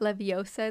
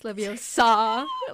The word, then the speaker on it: Leviosa: reminds me of Harry Potter, and it's Le- it's not Leviosa, it's Leviosa.